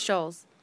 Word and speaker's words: Schulz: No, it's not. There's no T. – I think it's still pronounced Schulz. – Nope, it's Schulz.